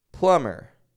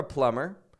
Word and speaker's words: plumber: No releasing the B. And if you are a listener and you are a plumber, now you know. You can be sure that you don't want to pronounce the B in your profession.